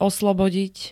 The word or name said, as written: oslobodiť